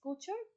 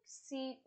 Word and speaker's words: couture: It's pronounced as couture, couture, and she goes on to work with Lo later, and oh my god, I love their fighting and banter and arguments.